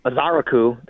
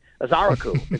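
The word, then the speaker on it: Ezeiruaku: Like, we went and looked at pronunciations, and it looks like the emphasis is on the second syllable from everything we saw, which is Azaraku. I'm sorry, Azaraku. See, there you go. I did it. Azaraku is how you say it.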